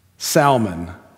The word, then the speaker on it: Salmon: Pronounced Salmon, not salmon. He is not a fish. He also is not a color.